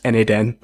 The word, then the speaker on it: n8n: So we just made it explicit, is like pronounced N8N.